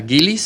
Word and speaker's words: Gillis: Espinosa Gillis, se escribe G-I-L-L-I-S, Espinosa es con S, como está ahí en el libro.